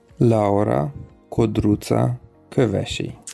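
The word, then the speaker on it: Laura Codruța Kövesi: Laura Kodruca Kövesi.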